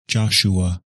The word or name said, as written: Joshua